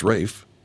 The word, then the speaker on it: Ralph: His brother is Rafe. It's pronounced Rafe, even though it's spelled Ralph.